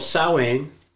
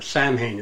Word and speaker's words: Samhain: It was called Samhain, or Samhain, it's spelled Samhain.